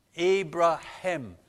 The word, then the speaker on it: Abraham: And there's this H in there. And in Hebrew, you pronounce it also. And Abraham.